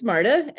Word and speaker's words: Marta: My name's Marta, and you did a beautiful job of pronouncing it.